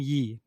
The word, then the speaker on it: Yee: It's Charlene Yee, not Lee. – Okay. – But it's spelled in an odd way.